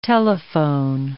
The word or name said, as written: telephone